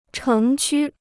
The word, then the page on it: 城区: chéng qū